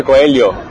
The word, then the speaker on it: Coelho: De hecho, se pronuncia Coelho.